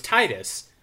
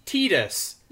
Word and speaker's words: Tidus: And then a couple years later, Kingdom Hearts came out, and Tidus was spelled T-I-D-U-S, by the way. So, like, of course you're going to say Tidus. Kingdom Hearts came out, and they tried to say that the guy's name was pronounced Tidus. As in, like, T-E-E-D-U-S, even though it's spelt T-I-D-U-S.